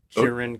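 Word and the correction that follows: Kyren: oh. Kyron. – It's Kyron. – Kyron. – I don't know where you came up with Kieran.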